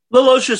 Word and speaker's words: LaLoggia: And this is a 1988 American supernatural mystery film directed, produced, and written and scored by Frank, am I going to say this right, LaLoggia? – LaLoggia sounds right. – LaLoggia, yeah.